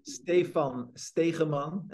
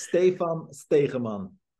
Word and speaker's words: Stephan Stegeman: My name is Stephan Stegeman and I'm Dutch. So it's kind of, you know, Dutchy if you want to pronounce it right. So it's Stephan Stegeman.